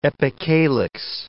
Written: epicalyx

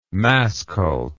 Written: masscult

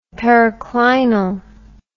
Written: periclinal

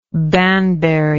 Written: Banbury

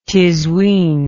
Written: tiswin

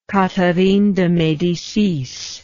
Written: catherine de medicis

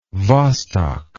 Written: Vostok

